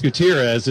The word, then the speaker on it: Gutierrez: No, it's Gutierrez is the way he pronounces it. But if you were to pronounce it phonetically, some refer to him jokingly as Gutierrez.